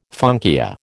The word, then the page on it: funkia: American English